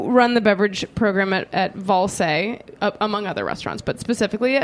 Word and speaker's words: Wallsé: About a robot is spelled W-A-L-L-E. So it's just like missing the S, but that's where like the dash is. It's a silent S, I think. Yeah, Valse.